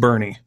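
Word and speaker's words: Boerne: It's it's G-R-U-E-N-E, and that's nope. Texas for you. It's kind of like Bowern, Texas. No, it's pronounced Bernie. – Bernie. – Get it right.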